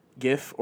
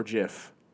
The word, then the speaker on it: GIF: Is it pronounced GIF or JIF? – Definitely GIF.